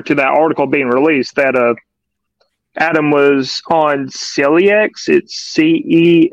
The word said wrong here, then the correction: Celexa: It's Celexa? – Fairly. Yeah, Celexa.